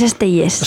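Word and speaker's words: Estellés: El Samans de Vicent Andrés Esteles. ¿Esteles es? – Vicent Andrés Estelles. – Estelles.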